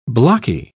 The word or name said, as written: blocky